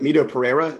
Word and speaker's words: Mito Pereira: Well, like Mito Pereira, is that how you say his name? – Yes.